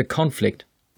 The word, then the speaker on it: conflict: Sie kennen das deutsche Wort der Konflikt und damit kennen Sie auch das englische Wort the conflict. Nun ja, die Betonung ist im Englischen auf der ersten Silbe und deshalb dürfen Sie sich die erste Silbe auch wesentlich größer vorstellen als die zweite.